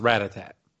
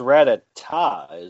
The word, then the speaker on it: Rattata: I never really figured out how to say this Pokemon's name right, but I believe it's pronounced Rattata. – It's Rattata, isn't it? – Rattata. Okay, yeah. – Because Rattata, that's like music, you know, Rattata tat tat.